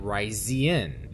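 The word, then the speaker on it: Risian: It is pronounced Ryza and Rycian. The S sounds like a Z.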